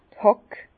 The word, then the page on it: d' Hàck: Bas-Rhin; Herrlisheim